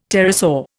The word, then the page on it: pterosaur: Indian